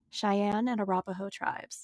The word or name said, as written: Cheyenne and Arapaho